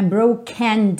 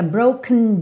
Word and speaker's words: broken: Percebe que esse broken, esse E não aparece muito, não é broken down, fica broken down, broken down ok? A sílaba tônica é a primeira,